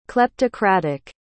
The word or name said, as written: kleptocratic